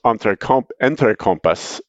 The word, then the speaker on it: Entrecompas: The last one is Entrecompas is the correct way to pronounce it. My man, Nick Herrera, tells me.